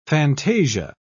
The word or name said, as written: fantasia